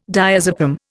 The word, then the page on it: diazepam: Indian